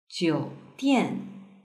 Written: jiudian

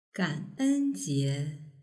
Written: ganenjie

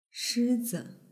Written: shizi

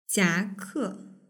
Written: jiake

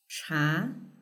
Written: cha

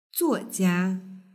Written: zuojia

